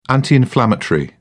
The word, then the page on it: anti inflammatory: British English